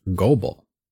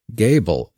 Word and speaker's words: Goebel: The name is spelled G-O-E-B-E-L, which, just reading, I would pronounce Goebel. But apparently that's not how it's pronounced by most, including the family themselves. Gable is the most common pronunciation of Kristen Joy Deedy's birth name.